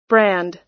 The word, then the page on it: brand: American English